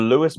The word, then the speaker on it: Lewis: You saying Louis completely sorry, random. I've actually it's quite the Lewis Moody Foundation. I've butchered it twice now.